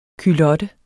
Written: culotte

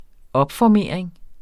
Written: opformering

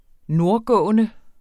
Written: nordgående

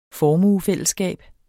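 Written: formuefællesskab

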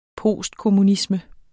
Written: postkommunisme

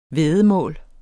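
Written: væddemål

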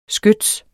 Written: skyts

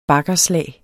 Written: baggerslag

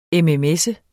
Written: mms

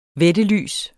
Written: vættelys

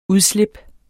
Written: udslip

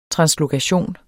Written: translokation

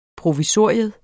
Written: provisorium

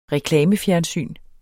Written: reklamefjernsyn

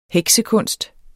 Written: heksekunst

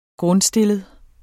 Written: grundstillet

